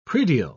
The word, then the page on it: predial: British English